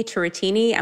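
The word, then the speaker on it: Turetini: And my name, so it's Uni Turretini.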